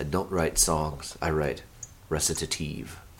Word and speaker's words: recitative: Recitative. – Recitative. It sounds so international. – It's an Italian word, so we're all saying it wrong.